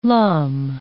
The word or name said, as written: lum